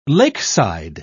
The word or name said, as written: lakeside